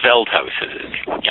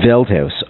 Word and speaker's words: Veldhuis: Have I pronounced his last name correctly? – Niels Veldhuis. – Yeah. Veldhuis, okay.